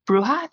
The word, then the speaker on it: bruja: This one is about a bruja in, I'm saying that wrong, John. I'm really sorry. Please pronounce it for me. – That's fine. – Okay. Bruja. I can't roll the R's.